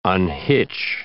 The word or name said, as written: unhitch